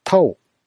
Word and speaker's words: 道: 中心となる概念に、道路の道と書いて、タオというものがあります。タオは根源的な宇宙の真理のことで、道教ではこのタオと一体化することで、不老不死になることを究極の理想とします。